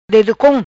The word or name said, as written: Dedocom